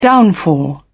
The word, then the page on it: downfall: British English